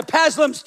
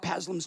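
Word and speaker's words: Psalms: Paslums 23. The one lady put her arm around my shoulder and she goes, young man, the P is silent.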